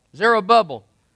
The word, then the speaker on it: Zerubbabel: Did I say that right? Zerubbabel. A bubble named Zera.